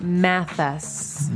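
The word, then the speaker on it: Mathes: Is it Mathis or Math? – Mathis. – How do you spell that? – Like Johnny Math? Mathis, so M-A-T-H, but E-S, not I.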